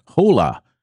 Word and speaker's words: Jolla: The operating system was created by a company from Finland named Hola, and it's spelled J-O-L-L-A, and the operating system is called Sailfish. – Huh? – Hola was formed from the ashes of Nokia, with most of its employees formerly working at that once great mobile phone company.